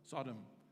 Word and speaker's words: Sodom: Sodom. Thanks, mate. Sodom. Okay, so it was a city. So they were probably on some kind of hill or mountain and they looked down on the city of Sodom.